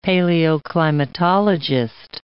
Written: paleoclimatologist

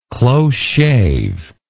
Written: close shave

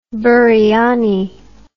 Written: biriani

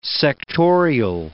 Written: sectorial

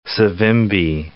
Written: Savimbi

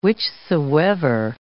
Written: whichsoever

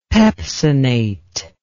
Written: pepsinate

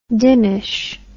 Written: dimmish

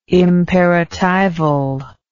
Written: imperatival

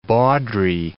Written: bawdry

